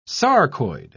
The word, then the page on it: sarcoid: British English